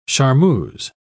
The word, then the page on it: charmeuse: British English